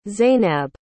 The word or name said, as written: Zainab